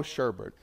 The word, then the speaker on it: sherbet: Rainbow sherbet. We pronounce it sherbet in the South, right? Even though there's no, the second R does not exist, you know.